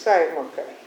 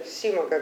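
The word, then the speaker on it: Simak: Если меня слышно, то я могу прочесть кусочек из Клиффорда Саймака, или Симака, как его правильно говорят, надо называть.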